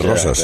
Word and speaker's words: Roses: Roses, le llaman Roses y no es Roses. En catalán es Rosas. – Como pera. – Roses. Claro, Rosas. Toda la vida ha sido Rosas.